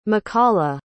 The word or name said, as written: mikalah